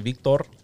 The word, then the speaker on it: Victor: It's actually Victor. – By your 20%? – If you guys can roll your R's when you address me, I would really appreciate that.